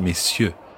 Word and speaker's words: messieurs: And note that you don't pronounce the R in messieurs like that, it's messieurs.